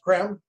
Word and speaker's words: Creme: And Benjamin Krem, spelled C-R-E-M-E. It looks like cream, but it's pronounced Krem, who has written about 17 books.